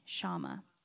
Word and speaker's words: Shammah: Shammah, which makes what Shammah did even more remarkable.